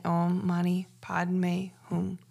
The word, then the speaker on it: Om Mani Padme Hum: It's also pronounced Om Mani Padme Hum with the H-U-N-G. That's just a difference in accent and pronunciations. But I am saying it Om Mani Padme Hum.